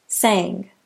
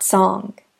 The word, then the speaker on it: sang: In Chinese, it sounds more like ong, which is spelled O-N-G. As in the word song. In English, the A is a long vowel, So the past tense of sing would be pronounced sang, not song.